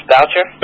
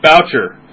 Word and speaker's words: Boucher: Now, these are, now, isn't Representative Rick, how do you say his last name? – I think it's Boucher. – Boucher.